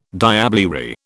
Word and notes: diablerie: British English

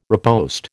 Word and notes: riposte: American English

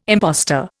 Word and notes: impostor: Indian

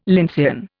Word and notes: lyncean: Indian

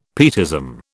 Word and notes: pietism: British English

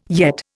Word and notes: yet: Indian

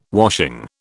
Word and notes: washing: British English

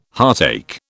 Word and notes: heartache: British English

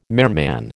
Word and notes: merman: American English

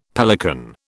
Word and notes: pelican: British English

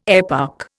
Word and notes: eparch: Indian

